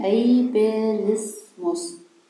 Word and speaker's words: Ableismus: Das spricht man Ableismus.